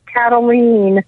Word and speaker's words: Kataline: Well, it rhymes with spleen, so it's Kataline.